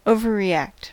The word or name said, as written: exagérer